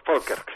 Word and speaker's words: Falkirk: William Wallace y sus hombres fueron derrotados en la batalla de, creo que se dice algo así como Falkirk, ¿no? – Falkirk en inglés. Falkirk. Pero bien, se escribe Falkirk, pero es Falkirk.